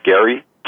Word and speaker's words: Geary: My name is Gary Sikich. First name is spelled G-E-A-R-Y. Last name, S like Sam, I-K-I-C-H.